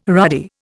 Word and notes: ruddy: Indian

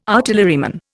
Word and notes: artilleryman: Indian

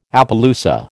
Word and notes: Appaloosa: American English

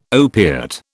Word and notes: opiate: British English

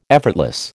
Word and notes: effortless: American English

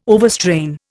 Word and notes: overstrain: Indian

